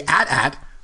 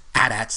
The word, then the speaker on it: AT-AT: He said, and I quote, "I say AT-AT for Imperial Walkers. Chicken Walker, that's A-T-S-T. Hope that's helpful," from Phil Tippett. So, Yancey, will you now accept the fact that the Imperial Walkers are pronounced AT-ATs, since it came right from Phil Tippett himself?